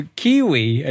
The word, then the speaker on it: Keywe: It's a puzzle game, but it's spelled K K E Y W E, like as in the word key and then the word we. It's a puzzle platformer game that came out,